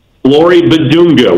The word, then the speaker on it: Flory Bidunga: Give me the pronunciation of the Kokomo kid, Flory Badunga. – Flory Badunga. We went with Badunga last year.